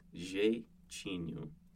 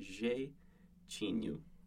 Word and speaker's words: jeitinho: So it's jeitinho. So if you think J-A-Y, as in a blue J, but not as sharp, it's kind of jeitinho.